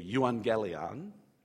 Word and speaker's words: euangelion: When you see that word gospel in Greek, to anglicize the Greek pronunciation, you would say euangelion. The EU uh, means good, and angelion, uh, we get our word angel from that word.